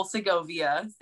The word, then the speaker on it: Segovia: It's Nicole Segovia.